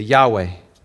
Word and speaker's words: Yahweh: It was just basically capital YHWH, say Yahweh.